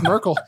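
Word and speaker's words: Myrkul: It's M-Y-R-K-U-L. Yeah, bring that cut on. – And I looked up the pronunciation and it's Merkel.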